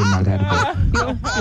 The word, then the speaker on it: Malherbe: I just started pronouncing the right way. Now it just started. I mean, like in my high school days, is Malherbe. You know Malherbe, M-A-L-H-E-R-B. Like you see Malherbe, you know, avenue wherever. You don't wanna know what I used to pronounce it as before. You don't wanna know, especially on radio. But only now I understand it. It's actually Malherbe.